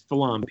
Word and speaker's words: philom: am I pronouncing that correctly? – Yes, it's it's it's, it's philom, p h i l o m period philam.